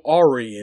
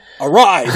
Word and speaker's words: awry: That's yeah, not how you pronounce that word. A-W-R-Y. How do you pronounce that word? Ari? Ari? – I don't... Arai? – Arai! There we go!